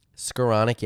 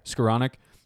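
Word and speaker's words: Skowronek: how do you pronounce it, Skoronic? – Skoronic, yeah. – Skoronic.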